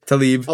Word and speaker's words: Tlaib: How do you pronounce that last Tlaib. – Name? Tlaib. – Although it is spelled T-L-A-I-B.